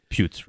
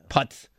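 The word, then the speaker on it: Puetz: P-U-E-T-Z, I got to be mispronouncing that. – Putz Road. – Putz.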